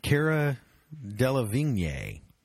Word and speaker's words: Cara Delevingne: She's in the running to play Harley Quinn, Cara Delevingne.